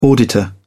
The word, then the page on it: auditor: British English